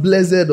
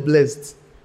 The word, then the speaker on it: blessed: Is that word pronounced blessed or blessed? It depends. It depends on which church you grew up in.